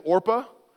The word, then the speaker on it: Orpah: Oprah Winfrey, her actual name on her birth certificate is Orpah, but it was mispronounced so often, she just went with Oprah, and that's a true story.